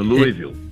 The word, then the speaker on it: Louisville: E eu tava vendo aqui também, de uma palestra que você fez na Universidade de Louisville. – Ah, isso, Louisville. – Essa pronúncia aí, você me corrige. – É a pronúncia que eles têm lá, porque o Louisville fica no Kentucky.